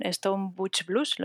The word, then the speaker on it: Stone Butch Blues: Pues mira, antes ya he mencionado Leslie Feinberg con Stone Butch Blues, ¿lo he dicho bien?